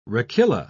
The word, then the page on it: rachilla: British English